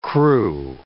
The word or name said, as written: krewe